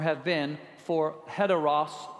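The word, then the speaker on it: heteros (ἕτερος): it makes an H sound. You got to put the H in front of it. If it's not, it's a silent sound. So those of you reading your Greek, the actual pronunciation is heteros.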